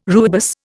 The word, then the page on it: rooibos: Indian